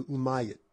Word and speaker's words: Umayyad: called the Umayyad Caliphate. It's spelled U-M-A-Y-Y-A-D, Umayyad Caliphate.